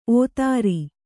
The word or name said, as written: ಓತಾರಿ